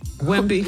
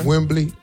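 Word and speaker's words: Wembanyama: Just Wimby. – Is what? – Victor. Wimbley. – Just call him Wimby, not Wimbley. Wimby. That's it.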